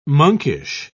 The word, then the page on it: monkish: British English